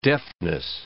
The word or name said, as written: deftness